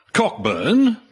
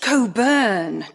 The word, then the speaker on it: Cockburn: Well, it's actually pronounced Coburn, Doctor. – All right, they must have had difficulty co-burning. – No, his name. It's written as Cockburn, but it's pronounced like the famous port.